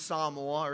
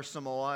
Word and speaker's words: Samoa: The actual name of the country is Samoa, or Samoa, as many people pronounce it.